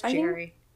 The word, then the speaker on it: Gerry: Gary, whose name is spelled like Jerry with a G, so it's confusing. All right, G-E-R-R-Y. Are we going to say Gary or Jerry? That's Jerry.